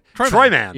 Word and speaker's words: Troy Mann: to say it like Florida Man. Troy Mann.